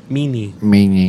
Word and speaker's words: Mene: Is it Mean or Mene? – Meanie. Like Meanie, Miney, Mo, I think.